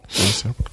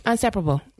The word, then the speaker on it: inseparable: Okay, inseparable. Unse- I mean, is that right? Did I say that wrong? Inseparable. – No. Inseparable. – Uh, what? Say it again. Isn't it inseparable.